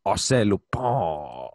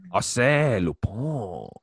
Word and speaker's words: Arsène Lupin: Well, if you use your gross American accent, but if you're speaking beautiful French, it's Arsène Lupin.